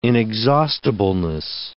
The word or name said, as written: inexhaustibleness